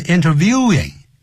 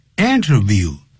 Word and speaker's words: interview: 记住不要念成 interviewing. Interview. 名词、动词都是念成 interview.